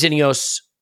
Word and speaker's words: Ineos: And now uh, the Glazers have sold a portion of their ownership in Manchester United Ineos. Am I saying that right? By the way, I, it occurs Ineos, yeah. Ineos, yes. yep.